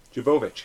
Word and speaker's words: Jovovich: Is it Mila Jovovich? – Jovovich.